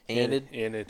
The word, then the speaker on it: Enid: Enid. Yeah, that's how we say it down south.